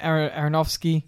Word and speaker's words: Aronofsky: So Darren Aronofsky, did I butcher the name? – No, that's right.